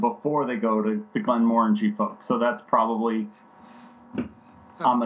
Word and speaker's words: Glenmorangie: Apparently, I'm going to mispronounce it, and I apologize. Glenmorangie, is that what you said? – Morangie, sorry.